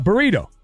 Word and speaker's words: burrito: It's a burrito. You know. – Burrito. – Yeah. – All right. Okay. – Okay. – Don't forget to roll your R's.